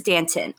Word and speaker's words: Danton: Yeah, first you said Dante. I thought you were trying to first say that this reminds you of Dante's Inferno, and I was like, okay. But then when you said Danton, but when you mispronounce it, I thought you were trying to say that he played D'Artagnan in Three Musketeers. No, you're not crazy. It is Danton.